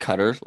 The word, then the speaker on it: Qatar: The Cutter Racing Sprint, spelt Q-A-T-A-R, yep. Pronounced Cutter, like the pitch.